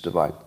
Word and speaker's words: Devine: Is it Divine or Devine? Divine? Miranda Devine or Devine? Somebody fact-check me. I'll say it correctly when you do. Vine. Divine. Okay, it's Divine.